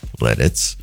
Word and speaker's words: Lititz: L-I-T-I-T-Z. I've got to be careful how I pronounce that. Lititz, I believe is how you say it.